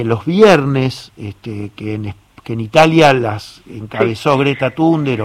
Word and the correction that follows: Thunberg: o Greta Thunder, como no sé cómo sí. se pronuncia. – Thunberg, Thunberg. – Thunberg.